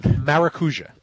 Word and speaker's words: Maracuja: Gosh, how do you say the Rob Atrus horse's name? Marajuka? God, I'm not Travis Stone. – Maracuja. – Yeah, whatever you just said.